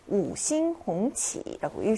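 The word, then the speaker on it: 우싱홍치라고 (五星红旗): 우싱홍치라고 읽습니다.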